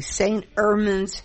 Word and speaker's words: St. Ermin's: We're sitting in the restaurant at the St. Ermin's Hotel, if I said that correctly.